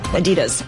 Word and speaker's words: Adidas: I go with Run DMC's pronunciation, Adidas.